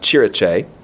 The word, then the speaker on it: cirice: You could spell it C-I-R-I-C-E. And it's pronounced chiriche, derived from West Germanic kirika, which in turn comes from the Greek kuriake.